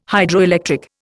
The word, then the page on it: Hydroelectric: Indian